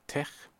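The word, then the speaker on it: TeX: Oder TECH muss man ja eigentlich korrekt aussprechen. Das griechische Chi.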